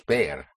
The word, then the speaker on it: ver: So to see is bear. It's like seeing the bear.